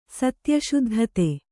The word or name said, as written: ಸತ್ಯಶುದ್ಧತೆ